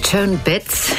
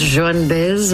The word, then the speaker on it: Baez: In Germany it's Joan Bitz, and in France it's Joan Baez, and in Spain it was originally Baez.